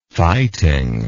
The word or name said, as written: fighting